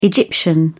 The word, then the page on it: Egyptian: British English